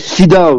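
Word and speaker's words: Llydaw: et en gallois, ça se dit Lidav, donc Lidav pour les gens qui prononcent pas le L,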